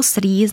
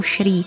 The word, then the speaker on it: Srí: Slovo Srí znamená v sinhálštině příznivý či zářivý. Podle některých jazykových příruček ho lze vyslovovat i jako Šrí. Sami obyvatelé ostrova používají hlásku mezi S a Eš.